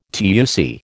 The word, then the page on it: TUC: American English